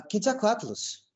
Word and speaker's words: Quetzalcoatlus: Uh Quetzalcoatlus.